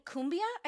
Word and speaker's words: cumbia: From Argentine cumbia, I think is how you say that. – Yes.